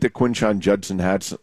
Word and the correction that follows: Quinshon Judkins: – Quinshon Judkins.